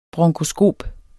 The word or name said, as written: bronkoskop